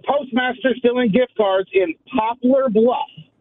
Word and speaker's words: Poplar Bluff: And I moved up this way in 2012, and it irks my soul and just like nails on a chalkboard when I hear people pronounce my hometown as Popular Bluff.